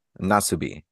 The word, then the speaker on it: Nasubi: Nasubi is how it was pronounced in Japanese.